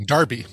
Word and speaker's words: Derby: Darby. – Darby. – Even though it says it's spelled Derby, but pronounced Darby. – It is Darby.